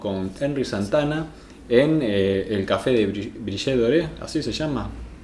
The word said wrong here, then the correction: Brioche Doré: Brioche Doré.